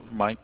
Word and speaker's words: Mike: Mike Kong. – I will spell it M-I-K-E. – M-I-K-E.